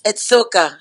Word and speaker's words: Soca: Because I was reading, they say you're the one of the first ladies of Socha, if I'm saying it right? – It's Soca. Soca.